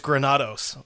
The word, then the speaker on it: Granados: It's Granados. I mispronounced.